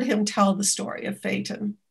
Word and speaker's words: Phaeton: It's one of the many names for a horse-drawn vehicle, like, how do you pronounce Phaeton? Is that about right? – PH? Phaeton, yes.